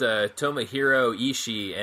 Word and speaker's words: Tomohiro Ishii: And it involved Tomohiro Ishii and Katsuyori Shibata.